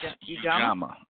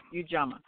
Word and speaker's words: Ujamaa: How do you pronounce that again? It's Ujamaa. – Yes. – Ujamaa.